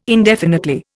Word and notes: indefinitely: Indian

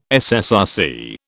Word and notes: SSRC: British English

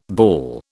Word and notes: bawl: British English

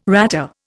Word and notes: ratter: Indian

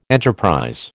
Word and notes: enterprise: American English

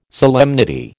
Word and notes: solemnity: American English

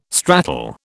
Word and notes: stratal: British English

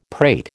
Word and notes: prate: American English

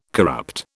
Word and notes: corrupt: British English

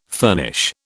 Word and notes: furnish: British English

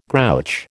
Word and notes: grouch: American English

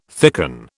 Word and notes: thicken: British English

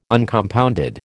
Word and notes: Uncompounded: American English

